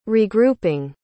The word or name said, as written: regrouping